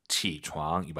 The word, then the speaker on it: 起床: 삼성이성. 아아. 起床. 이 발음 기억하세요.